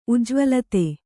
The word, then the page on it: ಉಜ್ಜ್ವಲಿತ: Kannada